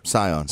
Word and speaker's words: Scion: Scion. – Yeah, you were saying Zion. I was like, whatever.